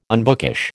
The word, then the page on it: unbookish: American English